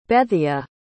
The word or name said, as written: Bethea